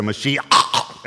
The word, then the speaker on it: Mashiach: He said Mashiach, and something came out of his throat when he said it.